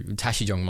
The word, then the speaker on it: Tashi Jong: And so Tashijong Monastery is the name.